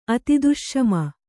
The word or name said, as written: ಅತಿದುಷ್ಷಮ